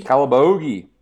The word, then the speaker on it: Calabogie: All right, we're back, and our next selection tonight for our second beer is, how do you pronounce that? Calabogie? – Calabogie, small town, Calabogie Brewing.